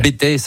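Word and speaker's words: Bettex: Le Bétex, certains disent, prononcent pas le X. Le Bétex, le Bétex. Enfin, il y a deux écoles, mais en tout cas une seule école de ski là-bas, et c'est là-bas où je suis allé.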